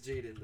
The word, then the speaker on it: Jadon: I'm pretty sure it's Jadon, though.